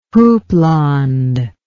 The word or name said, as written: houppelande